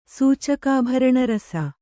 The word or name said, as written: ಸೂಚಕಾಭರಣರಸ